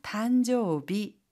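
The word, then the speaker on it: 단조비 (誕生日): 단조비. 이때 일이 비로 발음이 되죠.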